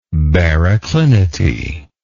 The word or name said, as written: baroclinity